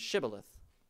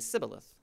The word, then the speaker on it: shibboleth: and the pronunciation of it was shibboleth. But the Ephraimites in their dialect, they didn't have that SH sound. They could only say sibboleth.